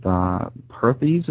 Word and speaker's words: Perthes: I mean, you start off talking about your birth and, you know, being finding out that you were afflicted with Perthes. Is that how you pronounce that? – Yeah.